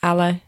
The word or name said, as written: ale